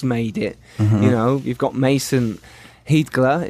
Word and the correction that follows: Heidger: Is that how you pronounce it? Heidgler? – Heidger, actually. – Heidger. – Yep.